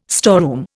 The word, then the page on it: storeroom: Indian